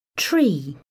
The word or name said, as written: tree